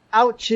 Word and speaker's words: autsch: Ouch is... – it's not a universal word. – It's from the German word ouch, A-U-T-S-C-H. So. – Yeah, it's spelled differently, but the pronunciation is the same.